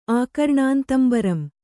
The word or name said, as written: ಆಕರ್ಣಾಂತಂಬರಂ